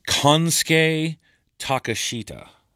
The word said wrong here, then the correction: Kosuke Takeshita: It was Kosuke Takeshita. It looks like Konsuke Takeshita, but that's not how you say his name.